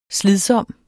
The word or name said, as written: slidsom